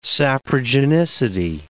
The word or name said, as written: saprogenicity